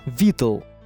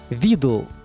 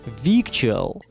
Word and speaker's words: victual: В итоге написание слова изменилось на «виктуал», а произношения остались прежними – во всех британских словарях вы увидите такую транскрипцию слова – «витл». «Витл». А так его читают американцы с точки зрения их же словарей. «Видл». «Видл». Но огромное количество носителей произносят странное слово как «victual».